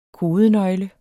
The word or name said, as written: kodenøgle